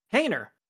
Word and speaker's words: Haener: And then Did you say Hader? – Hader. It's not Hader. How do you say it? – Hainer. There's Hainer. No T in there. – And yeah, there's no T. Hainer.